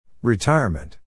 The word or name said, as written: retirement